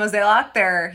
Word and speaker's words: Mozeliak: John Mozeliak, he is their president of baseball operations.